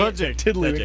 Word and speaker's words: Tudyk: Tidwick. – How do you say Tudyk.